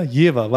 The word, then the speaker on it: Jever: Das Bier aber tatsächlich Jever mit W, wie Wilhelmine.